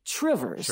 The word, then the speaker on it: Two Rivers: I mean, it's spelled like you can imagine, T-W-O-2 and then Rivers. But locals might say Trivers.